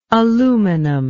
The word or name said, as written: aluminic